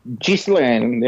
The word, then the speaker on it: Ghislaine: Ghislaine, yeah.